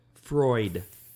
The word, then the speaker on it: Freud: Freud. Say Freud. – Freud. My mouth doesn't move that way. Freud. – What is wrong with you? Freud. – I don't say Freudian. Freud. – Freud.